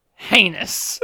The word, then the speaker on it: heinous: I like this bit where it's like, I know it rhymes with anus. Heinous.